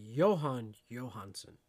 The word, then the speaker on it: Jóhann Jóhannsson: It's actually Jóhann Jóhannsson. So like a Y. Ah, fuck me. Just saying. It's got a J, man. – Yeah, we don't pronounce J's like a J.